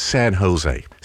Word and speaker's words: San Jose: He said, "Sir, in the United States, we sort of pronounce our J's like an H, so it's San Jose. San Jose, mate."